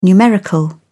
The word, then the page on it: numerical: British English